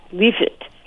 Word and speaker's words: lift: And in late summer, my grandmother would make leafit, which that's turnips, and it's spelled lift, L-I-F-T, but it's pronounced leafit.